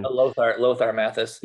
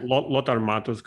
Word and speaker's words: Lothar Matthäus: Yeah, even Mataus, or I believe Lotaro, what is the name? I'm not sure about the correct pronunciation. – Lothar Matthäus, yeah. – Lothar Matthäus, correct.